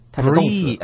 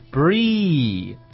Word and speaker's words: breathe: 这个 breathe 这个单词的发音是 breathe。哎，我们要分成两部分来读啊。首先，那个第一部分是 breathe，这个 e 的声音要特别的尖啊，特别这个清楚。 breathe 这第一部分，第二部分呢是 th 这个咬舌头这音，超出最佳发音。